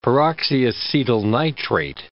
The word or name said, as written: peroxyacetyl nitrate